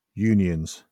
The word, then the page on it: unions: British English